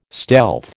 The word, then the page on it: stealth: American English